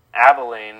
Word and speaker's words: Abilene: Brock always yells at me about it because I don't know how it's pronounced, if it's Abilene or Abilene. – Abilene, there you go.